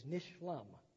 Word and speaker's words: nishlam: Finished in Hebrew is nishlam. Notice shalom. It's the passive of, it's peace. It's literally, or peace is made. Nishlam is the Hebrew for finished. I could break it into syllables. Nishalom. It's over.